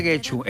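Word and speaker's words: Getxo: Yo me siento muy de Guecho. Es que ¿sabes qué pasa? Que no, Guecho, no, Guecho. – Guecho. Exacto, ahí es donde me equivoco yo, que lo vuelvo U.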